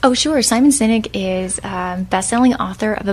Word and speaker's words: Sinek: And his name is spelled S-I-M-O-N, last name Sinek, S-I-N-E-K.